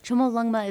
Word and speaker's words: Chomolungma: Chomolungma is the Tibetan name for Everest, and it means mother goddess of the universe.